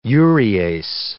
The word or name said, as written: urease